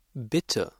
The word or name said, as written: bitte